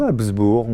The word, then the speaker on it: Habsbourg: Il s'agit ni plus ni moins de la résidence d'été de la famille impériale de Habsbourg. Ou Habsbourg, on peut ne pas prononcer le G.